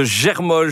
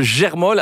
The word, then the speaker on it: Germolles: Ben, vous avez toutes les infos, bien sûr, sur le site du château de Germolles. Attention, ça s'écrit G-E-R, Moll, donc M-O-2-L. ES et la gastronomie.